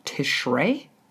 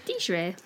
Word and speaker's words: Tishrei: On the first day of the Hebrew month of Tishrei, I'm not pronouncing that correctly. – Tishrei.